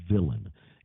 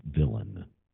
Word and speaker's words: villein: The term is villain. It's spelled V-I-L-L-E-I-N. It's pronounced, because of that European origin, the French part of it is it's spelled villein, but the English pronounced it villain.